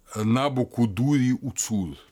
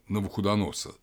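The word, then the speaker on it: Набу-кудурри-уцур: В личных значит, именах, вот мы знаем Навуходоносор, например, Набу Кудури Уцур на самом деле. Набу Кудури Уцур – это вот правильное произношение то и того имени, которое евреи слышали как э, Навуходоносор.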